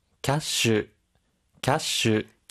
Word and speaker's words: キャッシュ: 캐 시 는 キャッシュ 라 고 다섯 박 자로 발 음합 니 다.